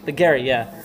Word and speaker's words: Paglieri: P-A-G-L-I-E-R-I. – Paglieri. – Paglieri, yeah.